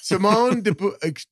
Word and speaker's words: de Beauvoir: It's just funny that she's one of the, like, Simone de Beauvoir. De Beauvoir. Audre Lorde. – De Beauvoir. – You're trying to say de Beauvoir. – Simone de Beauvoir.